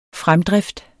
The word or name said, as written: fremdrift